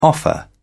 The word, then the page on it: offer: British English